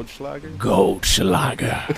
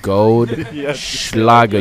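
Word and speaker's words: Goldschläger: Goldschlager. – The gold Schlager. You got to say it with the SH. Say it with me. Ready, ladies and gentlemen? Goldschlager. – The Schlager. – Goldschlager.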